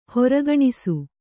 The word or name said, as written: ಹೊರಗಣಿಸು